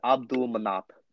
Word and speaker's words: Abdulmanap: It's Abdulmanap.